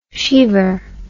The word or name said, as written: shiever